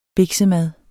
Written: biksemad

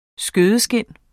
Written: skødeskind

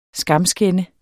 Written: skamskænde

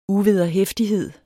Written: uvederhæftighed

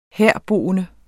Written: herboende

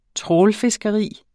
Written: trawlfiskeri

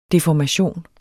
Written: DEFORMATION

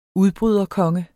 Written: udbryderkonge

